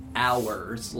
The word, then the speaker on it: hours: Hours, like, again, really difficult to enunciate the difference mm-hmm. between those two words, but like hours as in possessive nature. – Yeah, it's a silent H, so.